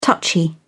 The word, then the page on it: touchy: British English